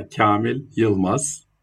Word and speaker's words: Kamil Yilmaz: Yeah, Kamil Yilmaz.